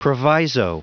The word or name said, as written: proviso